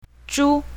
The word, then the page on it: zhu1: mandarin